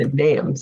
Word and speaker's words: Athenaeum: Athenaeum, sorry.